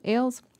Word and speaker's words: Ailes: Ailes.